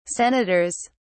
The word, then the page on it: senators: British English